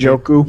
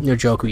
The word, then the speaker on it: Njoku: How do you say that? – Njoku. – Njoku.